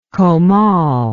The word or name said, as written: comal